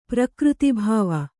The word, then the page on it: ಪ್ರಕೃತಿಭಾವ: prakřti bhāva